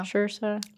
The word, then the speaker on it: Saoirse: You said it was like inertia. So inertia. – Yeah. – Saoirse.